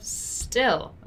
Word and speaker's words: still: First of them that I want to draw your attention to is the pronunciation of still, okay? It's different from steal and also from steal, okay? So steal, right? Still.